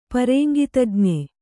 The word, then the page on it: ಪರೇಂಗಿತಜ್ಞೆ: parēŋgitajñe